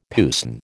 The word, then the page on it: puisne: American English